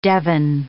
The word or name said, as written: devon